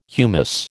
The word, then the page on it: humous: American English